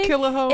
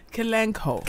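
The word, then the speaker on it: Kalanchoe: I think the sea is silent. – Hold on, I'm a botanist. I'll say. – Does that not say Killahoe? – Well, Killahoe. – Kalanko. – Kalanko.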